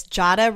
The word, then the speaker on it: Jadah: It's Jadah rhymes with Prada, right? – Yes, and Nada.